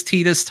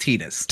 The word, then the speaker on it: Tidus: It's pronounced Tidus. Tidus. – Um, Tidus.